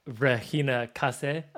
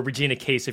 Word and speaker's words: Regina Casé: Like Regina Case, or Regina Case, if you're looking at it phonetically, if you're wondering who I'm talking about, is unbelievable.